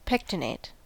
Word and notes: pectinate: American English

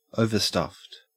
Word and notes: overstuffed: Australian English

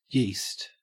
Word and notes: yeast: Australian English

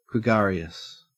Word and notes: gregarious: Australian English

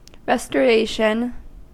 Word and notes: restoration: American English

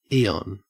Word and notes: eon: Australian English

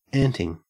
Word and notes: anting: Australian English

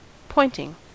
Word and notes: pointing: American English

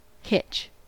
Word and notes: hitch: American English

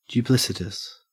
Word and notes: duplicitous: Australian English

